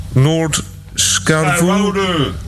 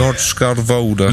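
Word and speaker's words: Noord-Scharwoude: And I can't pronounce this one. – You tried it. Do it again. – Noord Scharwoude. – You got it. – Noord Scharwoude.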